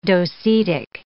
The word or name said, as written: docetic